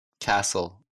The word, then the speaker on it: castle: T плюс U-R-E, U-R-A-L, U-R-Y. – Picture, future, nature, natural, century, question, fortune. – Буква T часто не читается в таких сочетаниях. F-T-E-N, S-T-E-N, S-T-L-E. – Soften, listen, fasten. Castle, apostle.